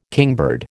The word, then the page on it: kingbird: American English